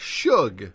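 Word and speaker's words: Suge: Shug. – Shug. What is that? – That's his name. – No, it's not. It's Sooge. – No, it's not Sooge. It's Shug.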